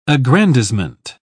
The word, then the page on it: aggrandisement: British English